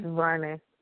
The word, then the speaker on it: DuVernay: DuVernay.